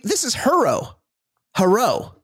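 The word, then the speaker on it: Herro: And the Heat have that guy, Tyler Hero. – Yes. – But it's spelled H-E-R-O. It's not Hero.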